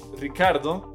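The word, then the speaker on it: Ricciardo: Entonces, Ricardo, porque él es de Australia y es como él lo acostumbraba a escuchar, pero dice que tiene origen italiano, sería Richardo.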